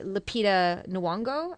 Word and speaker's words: Lupita Nyong'o: I think he's only refuted one claim, and that was by Lupita Nyong'o.